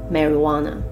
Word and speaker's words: marijuana: 那它有两个拼法，对，这边你可以 j u a，其实也可以 h u a 哦，但是发音是一样的 marijuana。